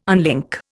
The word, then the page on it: unlink: Indian